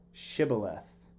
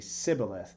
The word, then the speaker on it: shibboleth: Or like in the Old Testament, there was like this litmus test, and this group of people was told to say the word shibboleth, but because of their dialect or their, you know, where they were from, they could only say sibboleth. They couldn't pronounce like the sh together.